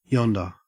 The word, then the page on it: yonder: Australian English